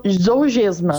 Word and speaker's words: Zolgensma: É, a gente viu que se chama, me corrija se eu estiver errado na pronúncia, mas se chama Zolgensma, seria assim? – Zougesma. – Zougensma.